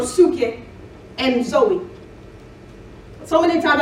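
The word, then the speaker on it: psuche (ψυχή): That word is psuche. Like I said, we've been commonly pronouncing it psyche.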